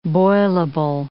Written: boilable

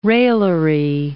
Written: raillery

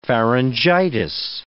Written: pharyngitis

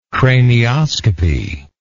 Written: cranioscopy

